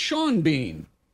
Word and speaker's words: Sean Bean: But there's an actor by the name of, it's spelled the same way. It's spelled Scene Bean, but it's Sean Bean.